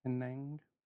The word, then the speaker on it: Penang: That's the city name, right? Penang? Yay! I got it right.